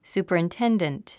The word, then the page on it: superintendent: sùːpərinténdənt; スーパリンテンデント